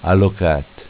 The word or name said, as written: Alocat